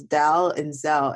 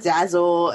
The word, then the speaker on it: Dalzell: Yes, but like my last name is literally two syllables, Dal and Zell, and I get like Dazzle, and I'm like, what?